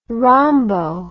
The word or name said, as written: rhombo